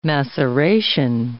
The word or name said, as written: maceration